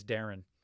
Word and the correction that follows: Deron: Deron. Pretty sure it's Deron.